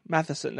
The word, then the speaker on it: Matheson: Matheson. That's how you say it.